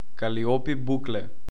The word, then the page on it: Калиопи Букле: kaliˈɔpi ˈbuklɛ